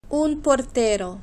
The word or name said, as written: un portero